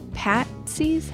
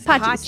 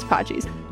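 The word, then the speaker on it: Patxi's: Patsy's? How do you say that? – Paxty's? – Pachi's. – Thank you. You were right. It was Pachi's.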